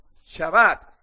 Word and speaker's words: Shabbat: No, se pronuncia Shabbat.